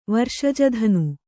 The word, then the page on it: ವರ್ಷಜಧನು: varṣaja dhanu